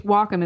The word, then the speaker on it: Wacom: The only reason I say it's Wacom is because one time I either had to call them or I called them to find out how to pronounce it. I can't remember. But the first thing you hear is, "Welcome to Wacom Industries."